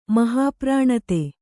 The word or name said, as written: ಮಹಾಪ್ರಾಣತೆ